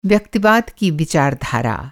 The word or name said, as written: व्यक्तिवाद